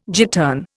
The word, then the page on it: gittern: Indian